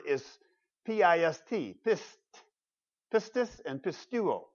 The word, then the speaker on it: pistis: You may not be able to pronounce it. That's okay. Pistis is that word.